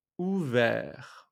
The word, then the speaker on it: ouvert: O-U-V-E-R-T. Ouvert, masculine version, and ouverte, with the extra E at the end. Ouverte, ouverte, feminine version.